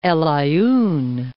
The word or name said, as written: Aaiun, El